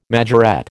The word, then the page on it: majorette: American English